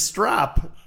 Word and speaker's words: strop: So is he saying strap or strop? – It's strop. STROP.